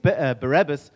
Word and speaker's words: Barabbas: At least Barabbas, or how do you guys pronounce Barabbas? Barabbas? Barabbas. Okay. At least Barabbas stood up.